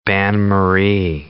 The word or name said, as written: bain-marie